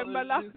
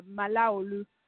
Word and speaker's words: Malaolu: Yeah, Malaolu. So, like, I feel like you have to say my surname with the accent, like Malaolu.